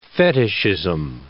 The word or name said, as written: fetishism